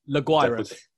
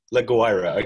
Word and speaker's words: La Guaira: It was interesting the way that Deportivo La Guaira, am I saying that correctly? – La Guaira. – La Guaira, okay, good.